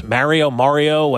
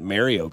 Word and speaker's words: Mario: Tell me I'm pronouncing it wrong, for God's sakes. Jesus. – Mario? – Yeah, what's Mario? The difference? Mario? I mean long A versus short A? – I don't think anybody calls it Mario Kart.